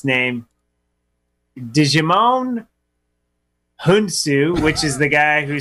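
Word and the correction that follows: Djimon Hounsou: Jimon Hunsu. It's like Jumanji. Hunsu.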